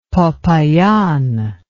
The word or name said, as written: popayan